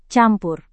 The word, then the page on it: campur: Indonesian